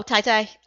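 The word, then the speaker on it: Tai Tai (太太): What's a Tai Tai, or am I saying that wrong? – Oh, Tai Tai, a housewife.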